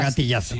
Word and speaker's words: gatillazo: Claro que pronunciando la Z, que es español, era gatillazo.